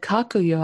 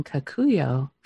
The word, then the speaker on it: Kakuyo: Now, I noticed you said Kakuyo. I've been saying Kakuyo. Have I been saying your name wrong this whole time? – Oh, no, it's correct. Actually, both pronunciations are correct. I have an accent.